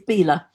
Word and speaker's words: Belaugh: Now, it's spelt B-E-L-A-U-G-H. I thought it was called Belaugh, but it's not, is it? – No, it's Bela.